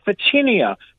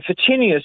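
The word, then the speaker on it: Photinia: There's a lovely planting that goes very well in Newcastle called a phytinia, and phytinia, spelt with a PH, gets a lovely red new leaf on it every time you trim it.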